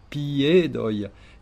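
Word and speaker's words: piedoj: Alors, là, attention, si on dit piedoi, on va mettre P-J-E-D-O-J, d'accord ? Et en fait, le J, on rappelle que c'est un I bref. Mais là, c'est bien un I qu'il faut dire, donc on va dire piedoi. Et en mettant l'accent tonique uniquement sur la lettre E, piedoi.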